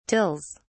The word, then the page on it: tills: British English